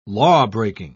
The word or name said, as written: lawbreaking